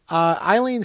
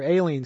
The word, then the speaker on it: Aileen: Eileen Stanley, or Aileen Stanley, as it is more proper, her name is more properly pronounced, in 1926, and this is again, this is about where I've been.